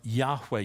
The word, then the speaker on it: Yahweh: How do you pronounce that? It doesn't have any vowels. Wasn't a problem back then before vowels were invented. They called him Yahweh.